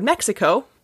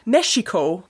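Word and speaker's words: México: But then Spanish kept changing, and not a lot of people spoke Nahuatl, and so Mexico became Mexico. – Ah. – Because the X sound was shifted from sh to h.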